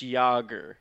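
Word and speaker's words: Jágr: No, yeah, it's actually pronounced Jager. It's a Jaromir Jager.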